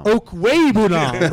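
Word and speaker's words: Okwuegbunam: How you say his name? – Albert Obuwekunam. Hey, I got that, dude. I got that. Where's Josh at? Where's Josh? Albert Obuwekunam. – Obuwekunam. – It's Obu. – Okwebunam. – Okwebunam.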